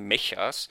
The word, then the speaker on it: Mecha: Der Film spielt in der Zukunft, ähm, in der die Herstellung von künstlichen Menschen schon sehr weit fortgeschritten ist, die sogenannten Mechas. Ähm, Im Englischen werden die als Mecha ausgesprochen, einfach von Mechanical.